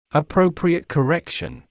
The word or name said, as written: appropriate correction